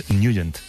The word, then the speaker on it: Nugent: Pero la pronunciación correcta es Nugent.